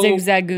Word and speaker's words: Zigzagoon: So, Zigzagoon.